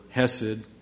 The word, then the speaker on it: chesed: The scholars tell us this word hesed, almost like sort of a silent C sound in front of it. Sometimes you'll see it spelled C-H-E-S-E-D.